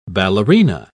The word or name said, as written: ballerina